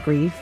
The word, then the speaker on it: Grife: I think you would pronounce it Grief. It is spelled G-R-I-F-E.